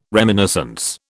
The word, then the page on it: reminiscence: British English